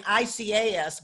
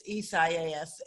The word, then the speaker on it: Isaias: I was saying Icaas, but it's Isaias, and it is a mouthful, and you kind of wonder where they pick up these flipping names. – Not a lot of letters yet, four syllables, so you kind of have to like think about it, obviously, before you say it.